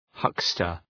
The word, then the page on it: huckster: ‘hʌkstər